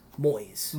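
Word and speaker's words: Moïse: So their president's name is like Moyes. I could be saying that wrong. – Malaise? – Moyes. – Moyes. – M-O-I-S-E.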